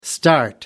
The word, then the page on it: start out: American English